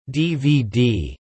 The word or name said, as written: DVD